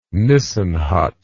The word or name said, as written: nissen hut